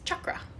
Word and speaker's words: chakra: Like chakra is chakra.